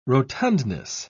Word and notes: rotundness: British English

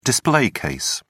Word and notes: display case: British English

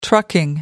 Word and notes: trucking: American English

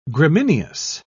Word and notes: gramineous: British English

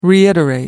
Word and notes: reiterate: American English

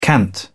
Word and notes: cant: British English

